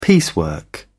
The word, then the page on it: piecework: British English